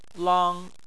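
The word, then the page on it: lang: tone 1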